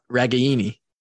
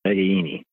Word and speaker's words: Ragaini: It's Ragaini. Pronounce the A and then Eni. – Yep. Ragaini. – Ragaini.